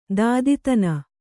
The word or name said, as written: ದೂತತನ